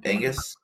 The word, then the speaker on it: Aengus: How would you pronounce this, Kelsey? I'm going to do this to you. – Okay. – Just because this is fun. A-E-N-G-U-S. – Agnes. – Angus. – I was close.